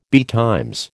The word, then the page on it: betimes: American English